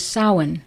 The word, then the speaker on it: Samhain: From Celtic tradition, which informs our Western culture, we received the celebration known as Samhain, which honored the spirits of the dead. By the way, Samhain is spelled S-A-M-H-A-I-N for those who thought it was pronounced Samhain.